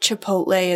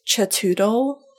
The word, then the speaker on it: Chipotle: Like Chipotle is Chatoodle.